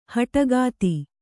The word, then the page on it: ಹೆಡಕತ್ತು: heḍa kattu